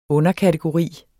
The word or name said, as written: underkategori